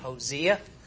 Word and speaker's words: Hosea: It's Hosea. The S-O is like Z-E-E, Hosea. And what's significant about Hosea relative to the northern kingdom? Anyone? I didn't have that as a question. Anything significant about Hosea and the northern kingdom?